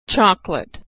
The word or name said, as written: chocolate